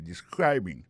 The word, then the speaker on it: describing: Alors, describing, nan, c'est participe présent, verbe to describe, ça nous ka wè là, ça nous constate là, nous ka constate que li a prononcé mwen de describing, accent tombe, sous deuxième syllabe